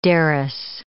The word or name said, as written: derris